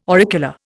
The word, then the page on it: auricula: Indian